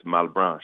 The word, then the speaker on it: Malbranche: It's a Haitian name, so you can give it a French flair. It's Malbranche.